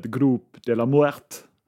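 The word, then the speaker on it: Grupo de la Muerte: För enligt denna så var det en mexikansk journalist som inför VM 1970 myntade begreppet Grupp de la Muerte. Bra uttal.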